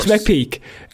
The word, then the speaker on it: McPeak: Peak is spelt, by the way, as in like peaking around the corner. It's Brett McPeak.